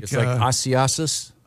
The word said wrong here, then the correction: Isaias: That's very, very close. It's Hurricane Isaias. Isaias.